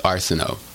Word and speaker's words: Arceneaux: Um, and it's Arceneaux, Michael Ar- Yes, Michael Arceneaux. – Okay, thank you. I've, I've, I thought that's what it was, but I didn't want to pronounce it incorrectly.